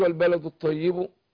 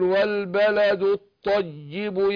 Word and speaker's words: والبلد الطيب: فهنا اقول والبلد الطيب التشديد، لكن مش والبلد الطيب، يبقى ما شددتهاش. قول والبلد الطيب يبقى عندي الطاء مشدده والياء بعدها برضو مشدده.